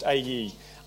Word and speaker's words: Ai: And after Jericho, the next city to be taken was a place called, it's spelled Ai, but pronounced A-E, a weak city that should have been easily conquered.